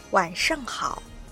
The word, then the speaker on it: 晚上好: 晚上好、晚上好。 – 漢字は今晩の晩という字ですね。そして上、好きと書いて晚上好っていう発音になります。これはさっきの早上好は早って省略しましたけど、こっちは晚っていうのはないですね。 – はい、はい、晚上好はこんばんはっていう意味なんですけれど、お気づきでしょうか。